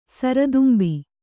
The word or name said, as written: ಸರದುಂಬಿ